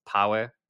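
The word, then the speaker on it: POAA: So this Proof of Active Agent, which they pronounce power, basically allows anyone to align an agent or entire agent economies with their goals.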